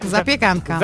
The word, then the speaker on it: zapiekanka: Zapiekanka.